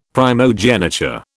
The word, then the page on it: primogeniture: British English